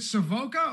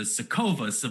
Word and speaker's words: Savoca: Matt, is it Savoca or do you pronounce it some different way that I may be butchering this soon? – You absolutely nailed it. I got to say it. Like, I usually people come in with there's all sorts of Sokovas, Savacas. No, you're perfect.